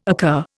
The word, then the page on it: occur: Indian